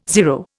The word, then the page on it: zero: Indian